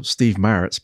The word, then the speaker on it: Maritz: And usually I remember you correcting me on my pronunciation of Maritz when I got it the wrong way around. – That's right. – Your way of correcting me was, well, Steve Maritz calls himself Steve Maritz.